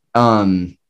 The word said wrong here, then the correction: Mateus: I can never pronounce his name. – Daryl Mateus. – Yeah, Mateus, thank you.